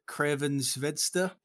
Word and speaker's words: Crvena Zvezda: So we saw Rangers lose to Serbian side Crvena Zvezda Belgrade. I think I pronounced that perfectly. – I think that's perfect pronunciation. Yeah,